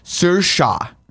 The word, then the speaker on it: Saoirse: Saoirse, that's how you say her first Saoirse.